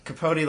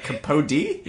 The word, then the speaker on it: Capote: Capote like Truman Capote. C-A-P-O-T-E. – How did you... I put a D in it. – Like Capody? – Yeah.